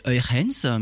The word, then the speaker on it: Huygens: Absolument, pour les pour les puristes, euh, je le prononcerai Eurens, mais bon, ce sera la seule fois dans l'émission. Huygens.